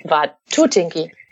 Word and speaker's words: Watutinki: Watutinki. – Watutinki und nicht Watutinki. – Betonung auf der 2. Nicht Watutinki, klingt nach Takatukaland. Nein, es ist Watutinki.